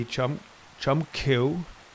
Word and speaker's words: Chum Kiu: Number two would be Chum Kiu. I always call it Q. It's C-H-U-M-K-I-U. Number three is Buji practice, B-I-U-J-E-E.